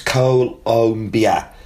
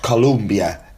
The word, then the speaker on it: Colombia: The country's name is pronounced Colombia, not Columbia, as I keep saying.